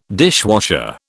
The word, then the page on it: dishwasher: British English